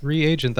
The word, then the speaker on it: reagent: Wait, am I saying it wrong when I say regent? – It's reagent. That's what they call it.